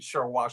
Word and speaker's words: Sharwa: And English people, you know, Westerners had a problem pronouncing Sharwa, Sharwa, and it turned into Sherpa, Sherpa, right?